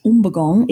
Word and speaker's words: Umbagong: And apparently Umbergong, which it's actually pronounced Umbergong, it's the native word for spear.